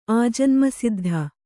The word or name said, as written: ಆಜನ್ಮಸಿದ್ಧ